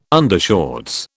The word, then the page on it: undershorts: British English